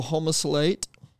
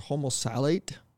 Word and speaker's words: homosalate: So homosalate or homosalate, okay, it might give you a better idea of how it's spelled, H-O-M-O-S-A-L-A-T-E.